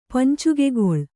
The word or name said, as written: ಪಂಚುಗೆಗೊಳ್